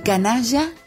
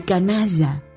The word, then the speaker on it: Canalla: Pero dejó una última obra, un regalo para el club de su vida, El Canalla, con Y, como se pronuncia en Rosario.